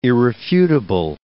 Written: irrefutable

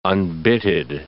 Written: unbitted

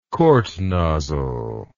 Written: kort nozzle

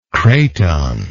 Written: cratonic

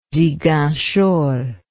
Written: Ziguinchor

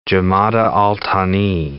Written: Jumada al-Thani

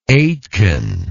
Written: Aitken

